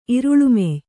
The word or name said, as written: ಇರುಳುಮೆಯ್